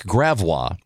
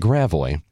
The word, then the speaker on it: Gravois: And nobody told them the local pronunciations, like Gravois, instead of Gravois.